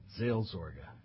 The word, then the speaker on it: Seelsorge: I think it's something like Seelsorge. Did I say that properly? Is that correct? Yeah.